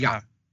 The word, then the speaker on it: Diop: As I have heard pronounced, it's like almost like Jop with a J, but Jop. It's Diop. D-Y.